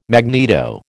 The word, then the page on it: magneto: American English